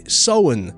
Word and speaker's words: Samhain: Bill O'Hurley, Irish man himself, he actually pronounces Samhain the correct way, Samhain.